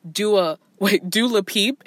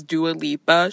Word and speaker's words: Dua Lipa: Dula Peep, aka, that's how Wendy Williams pronounces her name. Her name is Dua Lipa.